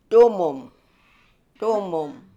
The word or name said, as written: Doomoom